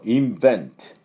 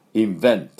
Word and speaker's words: invent: それから、発明、こういうものを発明することですね、なんていうかっていうと invent、 invent、この音ですね、 V の音をしっかり発音しましょう。じゃあ私の後について、どうぞ。 invent、 invent、はい、いいと思います。